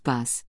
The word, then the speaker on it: boss: In India, when people have received the response they want in conversations they have initiated, they say "boss," pronounced "bus."